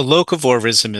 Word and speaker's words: locavorism: And let's start with one that just gets under my skin every time, which is locavorism. I think I'm pronouncing it right. What is that about, and why is it wrong? – So locavorism is a movement that began in 2005.